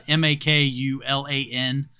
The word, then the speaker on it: Makulan: I couldn't actually find how Marvel expects you to pronounce that, but it's uh, M A K U L A N. Uh, so I'm pronouncing it Makulan.